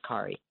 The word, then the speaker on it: Kari: My grandmother was Norwegian, and so they spelled it the Norwegian way, and that's how you would pronounce it in Norway. It would be pronounced Kari.